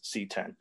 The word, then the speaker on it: CX: LG CX pronounced C10.